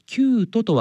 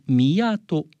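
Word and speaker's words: 宮都: この飛鳥・藤原のキュート、キュートとは漢字で宮に都と書きますが、世界遺産の決定ニュースの際、私が AI に読み方を聞くと、飛鳥・藤原の宮戸と教えてくれました。宮戸という読み方もあるのかもしれませんけど、世界遺産登録の読みは旧都で統一されています。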